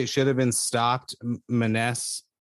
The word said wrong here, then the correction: Maness: Manus. It's yeah, again, it's not, right? Not phonetically anyway.